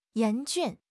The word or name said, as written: 严峻